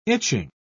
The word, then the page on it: itching: British English